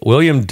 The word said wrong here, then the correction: Willem: No, I said it wrong. It's Willem. Look at that. See? Willem. W-I-L-L-E-M.